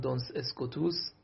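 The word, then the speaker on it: Duns Scotus: حالا تلفظش هم من لاتینی عرض میکنم، دونس اسکوتوس خودش اهل اسکاتلنده و تلفظهای مختلفی هست، معمولا دونس اسکات هم مینویسن، ولی ما رو همون تلفظهای لاتینی که زبان رسمی همون موقع بوده اسکوتوس از این جهت مینامیم.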